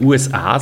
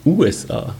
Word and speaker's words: USA: Das taugt mir, dass ihr Schweizer äh, nicht USA sagt mit Betonung auf A, sondern USA mit Betonung auf U.